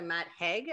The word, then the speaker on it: Matt Haig: All I know about it is I like the colors, and it's called The Midnight Library by Matt Haig. Am I saying it right? – Sounds right.